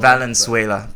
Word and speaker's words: Valenzuela: Valenzuela? – I usually say Valenzuela. – Valenzuela.